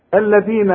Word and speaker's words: الذين: الذين آتيناهم، ما فيش أبدا مشكلة في نطق هذه الكلمة عند الجميع لأنها كلها حروف مرققة، لكن طلع لسانك في الذال.